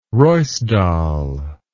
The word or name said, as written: ruisdael